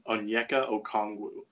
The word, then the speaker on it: Okongwu: Now I want to talk about a big man, Onyeka Okongwu.